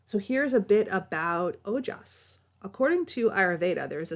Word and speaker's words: ojas: This substance is called ojas, and that's spelled O-J-A-S.